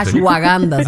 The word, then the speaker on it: Ashwagandha: Bueno, se tomó la chaguanda, él se la tomó ese día. – Ashwagandha se llama.